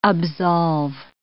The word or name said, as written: absolve